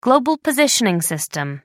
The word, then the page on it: global positioning system: American English